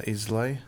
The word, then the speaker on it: Islay: Galila, And uh, it's from Ilay. How would you pronounce that? – Uh, Islay.